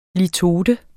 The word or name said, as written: LITOTER